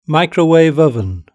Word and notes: microwave oven: British English

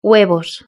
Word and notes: huevos: Spain Spanish

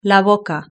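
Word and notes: la boca: Spanish (Spain)

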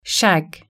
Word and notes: shag: British English

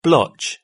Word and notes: blotch: British English

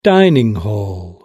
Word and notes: dining hall: British English

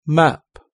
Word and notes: map: British English

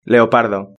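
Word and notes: leopardo: Spanish (Spain)

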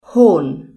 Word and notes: horn: British English

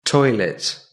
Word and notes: toilet: British English

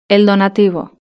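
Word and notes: el donativo: Spanish (Spain)

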